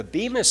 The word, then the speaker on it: Bema: The Bema Seat judgment, this is based on a Greek word that's pronounced Bema.